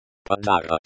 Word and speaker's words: подарок: The stress is on the second syllable of подарок.